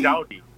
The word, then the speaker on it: doughty: It's spelled D-O-U-G-H-T-Y. So it looks like dough. So you put a T-Y on it, and the pronounce is totally different.